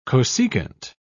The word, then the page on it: cosecant: British English